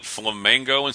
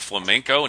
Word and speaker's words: Flamenco: Did you hear about the guy, Wheel of Fortune, who pronounced it Flamengo instead of Flamenco, and he lost $7,100? – Ouch.